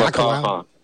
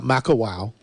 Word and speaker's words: Makaha: Let's say hi to Makaha, Hawaii. Did I say that right? – Makaha. – Not Makawow.